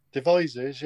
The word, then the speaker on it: Devizes: Have you heard of a place called Devizes? Am I saying it right? – Devizes, yes.